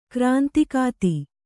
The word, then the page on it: ಕ್ರಾಂತಿಕಾತಿ: krāntikāti